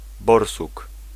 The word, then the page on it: borsuk: /ˈbɔrsuk/